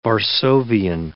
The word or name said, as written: Varsovian